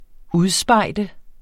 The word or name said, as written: UDSPEJDEDE